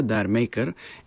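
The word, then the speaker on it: D'yer Mak'er: Самото заглавие на песента, Dire Maker, е игра на произношението на Джамейка с английски акцент, което загатва за влиянието на реге в ритъм и мелодията, отклонение от типичния хард-рок звук на Led Zeppelin.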